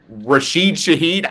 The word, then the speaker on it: Rashid Shaheed: I'm down 36.5 points, but I still have two players left to play, and that is Patrick Mahomes and Rashid Shaheed. I can't pronounce. Did I pronounce that right this week? – You did. That sounded great to me.